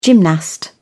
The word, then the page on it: gymnast: British English